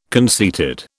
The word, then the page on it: conceited: British English